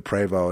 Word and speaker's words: Prevost: I can't, the only thing I know about it that where there was some evidence was when I took it into the Prevo. It's called Prevost, but it's French, so it's pronounced Prevo.